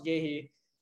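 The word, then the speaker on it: Guéhi: Honestly, I'm just hyping on the Gihi train. I can't pronounce his name. – Gehi, thank you.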